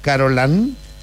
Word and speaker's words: Carolán: Carolán Peña. – Muy bien, Carolán, ¿se escribe Line? – No, Carolán, L-A-N. – Muy bien. Muy bien, Carolán Tello.